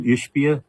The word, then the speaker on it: Ishbia: And I think it's when Mr. Is that how you pronounce his name? Ishbia? – Yes.